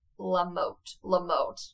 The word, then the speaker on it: Lamotte: Elizabeth Monette. Lamotte. Oh my goodness, I butcher these names so bad. Lamotte? L-A-M-O-T-T-E. Her DNA was obtained by New Hampshire police in 2017, and results were concluded that the victim was Elizabeth.